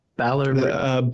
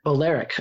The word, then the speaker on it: Balearic: How do you say it again? – Balearic. – Balearic. Okay, good.